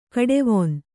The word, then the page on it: ಕಡುವೇನೆ: kaḍuvēne; Kannada